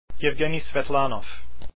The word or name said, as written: Svetlanov